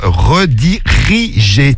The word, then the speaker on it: redirigé: Il oublie une syllabe. Redirigé. T'as oublié redigé. Non, c'est redirigé sur Snap.